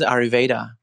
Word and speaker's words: Ayurveda: 阿育吠陀应该是 Ayurveda